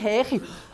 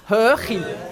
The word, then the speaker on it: Höchi: Hö, ja, nicht Höchi, Höchi. Nochmal? Höchi. Höchi. Ja, Höchi. Höchi. Ja, genau. Gut.